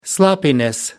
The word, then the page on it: sloppiness: British English